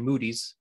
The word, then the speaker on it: Moody: And so Moody, if you're from Chicago, it's actually Moody's. That's how we pronounce it. Go to Moody's. Right. We had S's to everything up there for whatever reason.